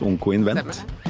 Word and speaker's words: Oncoinvent: Eh, tusen takk, Ingrid Teigland Akai, eh, gründer og partner i Hadin Ventures, og Kari Myhren, chief medical officer i Onkoinvent. Det det er sånn det uttales. Onkoinvent.